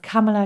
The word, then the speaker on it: Kamala: Und so kann ich immer erinnern, dass Kamala klingt wie Mamala, also Mom, also Mutter. Also, ja. Insofern Kamala, also nicht Mamala. Kamala, so wie Pamela oder nicht Kamala, kurze Aussprachehilfe. – Kamala, der Druck auf die erste Silbe? – Silbe, ja. Silbe. Also Pronunziation vorne, Aussprache vorne, Kamala Harris.